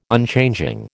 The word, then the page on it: Unchanging: American English